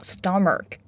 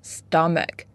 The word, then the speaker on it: stomach: 这个单词做形容词呢，表示南极的。如果要指南极地区，需要在前面加上定冠词 the the Antarctic。Next one claw claw 指兽类或者鸟类的爪、脚爪。Next stomach stomach pay attention it's not stomach it's stomach 指胃、腹部。Next word colony colony here it means a group of animals that live together.